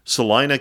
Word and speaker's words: Salina: And the men's team, along with the women's team, will head to Salina, Kansas. I believe I said that correctly. Home of Kansas Wesleyan. – That is correct.